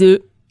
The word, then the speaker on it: œufs: On écrit un œuf donc au singulier et des œufs et on ne prononce pas le F au pluriel.